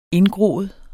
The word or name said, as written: indgroet